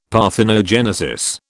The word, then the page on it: parthenogenesis: British English